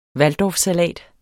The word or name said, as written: waldorfsalat